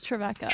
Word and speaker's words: Trevecca: And am I saying this right? Is it Trevecca? – Yes, Trevecca. – Trevecca mm-hmm.